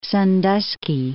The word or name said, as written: Sandusky